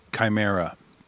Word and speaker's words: chimera: It's actually pronounced Chimera. C-H-I-M-E-R-A. Chimera. All right.